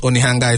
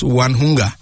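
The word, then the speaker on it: Onehunga: I know I got a friend from Nigeria who first came here, and how he pronounced Onihanga is Wanhunga.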